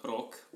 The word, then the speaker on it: Rok: Well, my name's Rok. In Slovene, it's called Rok, but everybody seems to call me Rok because that's how they do it. Mm. – So, wait, how do you spell it? – R-O-K.